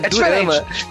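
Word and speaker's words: durama (드라마): E uma coisa também que é interessante falar é, Dorama é a pronúncia japonesa e quando a gente fala, eu pelo menos me refiro a japonês. Porque em coreano, como que fala? Ih, cara. é, é durama.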